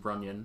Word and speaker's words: Runyan: Uh, John Renan out of Michigan. It might be Yan, actually, but who knows? Uh, six. I believe it is pronounced Runyan.